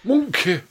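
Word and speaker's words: Mongkay: And then I was like, it can't be pronounced Monkey, because it's M-O-N-G-K-A-Y? – Monkey. – Yeah. But I looked it up and I was like, no, it is pronounced monkey.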